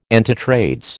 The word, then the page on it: antitrades: American English